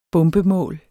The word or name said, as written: bombemål